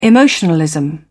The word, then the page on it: emotionalism: British English